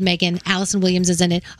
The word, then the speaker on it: M3GAN: If you get a chance to watch this trailer, the movie is called Megan. It's spelled M3GAN.